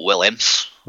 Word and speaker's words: Willemse: I'm pronouncing it like that because it's spelled W-I-L-L-E-M-S-E, so I'm pronouncing it Williams. – I'd go Williams.